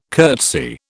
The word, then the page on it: curtsy: British English